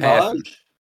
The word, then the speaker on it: Cambage: Listen, I learned uh Liz Cambridge Cambage. Now I know it's it Cambage. – It's Liz Cambage. – Cambage.